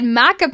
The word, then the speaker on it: maca: And I add maca, is that how you say it? – Maca, yeah.